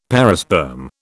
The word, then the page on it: perisperm: British English